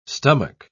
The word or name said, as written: stomach